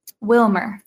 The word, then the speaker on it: Wilmore: Wilmore, Kentucky. – Wilmer, that's it, yeah. Kentuckians would say Wilmer.